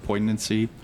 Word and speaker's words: poignancy: It can really help raise the comedy level to how do you say the word, poignancy? – Poignancy.